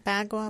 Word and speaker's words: Bagua: Bagua, yes.